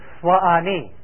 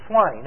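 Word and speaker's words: Suwannee: Swa'ani. But he, of course, took a little license and dropped one syllable and became Swanee.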